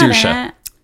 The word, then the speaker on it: Saoirse: Her name's pronounced Saoirse.